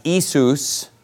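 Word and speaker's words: Isus (Ιησούς): So he's always called Jesus, which in the Greek tongue is spelled more like I-E-S-O-U-S. And then, yeah, Jesus, exactly. And then in modern Greek, it would be pronounced Isus, okay?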